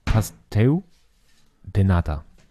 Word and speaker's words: Pastel de Nata: Pastel, aber ich glaube, das L hat im Portugiesischen eher so ein U. Die sagen auch nicht Portugal, sondern Portugal. – natürlich viel portugiesischer ausgesprochen, deswegen würde ich sagen, Pasteu de Nata.